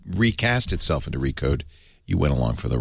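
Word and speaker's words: Re/code: Now, Liz, do I say Recode now? That's the official name. – Yeah, you don't have to pronounce the slash. It's silent. – Re-slash code. – No, you don't have to say that.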